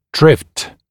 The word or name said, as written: drift